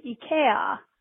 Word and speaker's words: IKEA: And I just wanted to let you know that the proper pronunciation of IKEA in Scandinavia is IKEA. The I sound is like an E sound here, so it's IKEA.